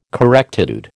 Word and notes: correctitude: American English

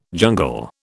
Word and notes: jungle: British English

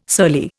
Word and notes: surly: Indian

